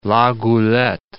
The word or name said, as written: La Goulette